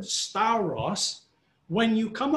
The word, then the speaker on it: stauros: The Greek word 4716, stauros. That's how you pronounce it, stauros.